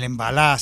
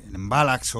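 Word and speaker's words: mbalax: El embalá senegalés, el embalax o embalá en francés pronunciado, también es un ritmo que está teniendo mucha repercusión en muchos bailes y en muchos ritmos bailables que se están utilizando.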